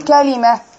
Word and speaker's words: كلمة: بفتح الكاف وأكسر اللام وأفتح الميم، أقول كلمة وجاءت في القرآن.